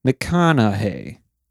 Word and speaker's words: McConaughey: Mick, M-C. Then Con. Then Aug, like August. – Mm-hmm. – Then Hey, McConaughey.